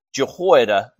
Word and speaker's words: Jehoiada: And now, obviously, that's not the way you pronounce his name in Hebrew, because they didn't have the J sound. It would have been Jehoiada, and it would have been more of a Y sound.